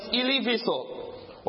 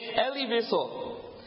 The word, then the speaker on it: Elie Wiesel: His name was Eli Wiesel. Or as it says, sometimes the Americans say, Elie Wiesel.